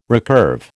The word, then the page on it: recurve: American English